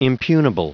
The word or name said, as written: impugnable